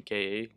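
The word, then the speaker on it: KÀ: If you don't like the name K-A, there's something wrong with you. But, or it's not even pronounced K-A, it's Ka.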